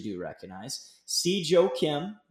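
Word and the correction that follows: Si Woo Kim: Did I say that right? – Siwoo Kim.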